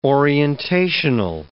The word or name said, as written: orientational